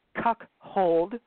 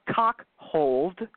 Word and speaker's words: cuckold: First of all, the word is pronounced cuckold, not cuckold or cockhold, cuckold, okay? That's one of my pet peeves. Say it right. It's C-U-C-K-O-L-D. There's no H in any of that, okay?